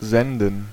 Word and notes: senden: /ˈzɛn.dən/

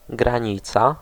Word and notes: limit: American English; /ˈlɪmɪt/

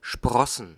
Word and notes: germer: France; [ʒɛʁ.me]

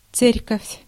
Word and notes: Kirche: standard; /ˈkɪʁ.çə/; [ˈkɪɐ̯çə]

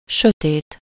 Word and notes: sombre: France; [sɔ̃bʁ]

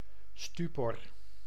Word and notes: stupéfaction: [sty.pe.fak.sjɔ̃]; France

